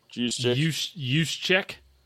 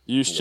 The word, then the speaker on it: Juszczyk: Juszczyk. – Juszczyk, yeah.